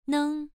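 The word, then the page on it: neng: tone 1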